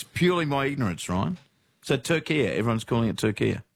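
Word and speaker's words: Türkiye: But in yesterday's Socceroos updates, you kept saying Turkey and not... – Turkia. – What's doing? I never heard of Turkia, Ryan, is my honest answer. – Yeah. – Never, ever, ever heard of it pronounced that way.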